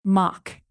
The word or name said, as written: mock